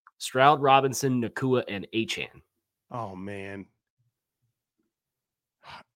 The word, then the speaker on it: Achane: You know, because I said I think I said Chain in like March and got corrected to A Chain, and now I've been saying A Chain, and he was like, actually, it's pronounced A Chan.